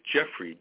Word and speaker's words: Jeffrey: And then my middle name is Jeffrey, J-E-F-F-R-E-Y.